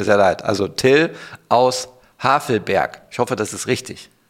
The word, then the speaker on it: Havelberg: Ich habe mal Havelsberg gesagt, mit W gesprochen, oder Havelberg besser gesagt, und dann Havelsberg mit S, das ist alles nicht richtig.